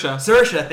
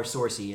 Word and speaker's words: Saoirse: Saoirse, thank you.